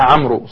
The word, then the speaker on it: عمرو: مثلا مثل واو الجماعة، مثل الواو اللي في عمرو، ما حد ينطقها عمرو صح، ولكن يقولون عمرو، والواو يقولون للتفرقة بينه وبين عمر مثلا.